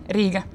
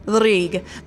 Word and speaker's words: Riga: And you pronounce it Riga? – Riga. – Riga. But you spell it R-I-G-A.